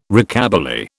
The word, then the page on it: Rockabilly: British English